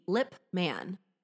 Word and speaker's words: Lipman: It's literally L-I-P Lipman.